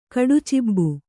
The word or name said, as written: ಕಡುಚಿಬ್ಬು